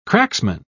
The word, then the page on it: cracksman: British English